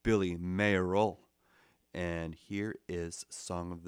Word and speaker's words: Billy Mayerl: I say Billy Mayerl, and his last name is spelled M-A-Y-E-R-L, Mayerl. But he's British, so they would say Billy Mayerl, you know, because British people say, you know, boys and girls.